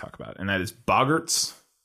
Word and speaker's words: Boggarts: as I pronounced it when I read the book, Bogarts. – Yeah. – When I read it, they were Bogarts, and then I saw the movie and they're like Boggarts, and I'm like, all right.